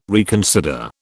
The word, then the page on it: reconsider: British English